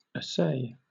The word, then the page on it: assay: British English